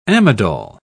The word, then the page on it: amidol: British English